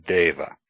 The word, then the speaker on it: Deva: E, pronounced A, is like the EI in rain, as in Deva, God.